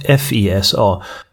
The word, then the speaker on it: FISA: Vi går tillbaka och säger också att till att börja med kan vi säga att FISA, det är de här märkliga begreppen som vi med fördel uttalar på engelska snarare än svenska. FISA, Foreign Intelligence Surveillance Act, som är en lag som tillkom 1978.